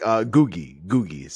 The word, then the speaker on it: Ngugi: Yeah, it's like Ngugi, so the N is silent.